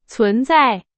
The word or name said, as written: 存在